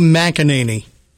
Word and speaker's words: McEnany: Is it McEnany? – I think it's easy. It's Kaylee McEnany.